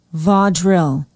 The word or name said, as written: vaudreuil